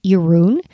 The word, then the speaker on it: Jeroen: His name is Jeroen, which is a Dutch name. You pronounce it like the color maroon. You just say ja instead of ma, something I have learned over the many, many years that we've been together to explain to people how to say his name.